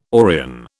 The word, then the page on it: Orion: British English